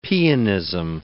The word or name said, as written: pianism